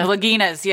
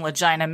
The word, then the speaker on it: Laginas: Laginas, yes, sorry, Laginas. Because it's spelled like Legina. – It is, yes. I think we called them Legina many times to ourselves.